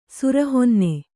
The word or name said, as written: ಸುರಹೊನ್ನೆ